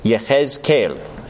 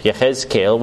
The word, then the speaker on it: Yehezkel: Ezekiel, the Hebrew title for this book is Yehezkel. Yehezkel, which is the way that you would pronounce Ezekiel in the Hebrew language.